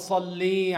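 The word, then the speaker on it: Salli (صلِّ): Salli. Lam with kasra only. There is no ya after the lam. Salli. Allahumma salli ala Muhammad. This is how you say it.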